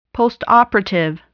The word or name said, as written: postoperative